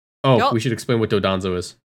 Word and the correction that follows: Dondozo: Or Dondozo. Sorry, Dondozo. We can't say it right now.